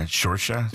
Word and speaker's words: Saoirse: Her name is not pronounced the way it's spelled. – Yeah, Saoirse.